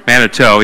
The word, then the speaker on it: Manitou: I have written at the top of my paper here, Manitou, right? Or Manitou. How do you say the road out here? Manitou, yeah.